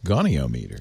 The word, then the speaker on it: goniometer: It's anyway, got to be pronounced goniometer.